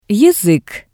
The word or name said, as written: yazik